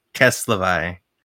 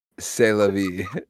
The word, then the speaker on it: c'est la vie: Dog, it's c'est la vie. – No, I took French, all right, for 3 years. I know for a fact that c'est la vie is correctly pronounced qu'est-ce la vie, all right? – If you're right, then you're right.